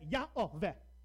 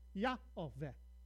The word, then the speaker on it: Yahweh: It wasn't to tell you how to pronounce it. It was to remind you to say Adonai. But somewhere along the line, we decided that must have been how they wanted to pronounce it. So if you translate it directly from Hebrew, you get Yahweh. Adonai. Yahweh. Yahweh. If first you take it to Latin, you get Jehovah.